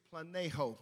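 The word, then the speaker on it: Planeho (πλανάω): The word seduced in the Greek is planeho. Planeho is how you pronounce the Greek word.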